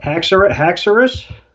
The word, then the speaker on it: Haxorus: Haxorus? – Yes.